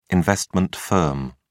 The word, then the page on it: investment firm: British English